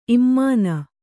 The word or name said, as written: ಇಮಾನಿ